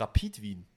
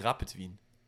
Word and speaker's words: Rapid Wien: du hier, es heißt auch Rapid Wien und nicht Rapid Wien.